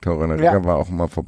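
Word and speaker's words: Torunarigha: Die nutzen ihre Chancen einfach extrem gut, haben auch, wie spricht man aus, Torunariga? – Torunariga.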